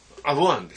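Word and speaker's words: 英虞: 賢島駅到着後、栄領庵って読むのかな、これ。 – アゴ庵ですよ。 – これ、アゴって読むのこれ。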